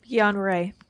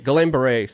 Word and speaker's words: Guillain-Barré: Guillain-Barré. – Guillain-Barré. – Just say it really quickly so no one knows. – Guillain-Barré. – You mispronounced it. Guillain-Barré syndrome.